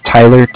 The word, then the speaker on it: Tyler: But my name is Tyler, T-Y-L-E-R, and I'm calling with Cardiff. It's spelled C-A-R-D-I-F-F, as in Frank.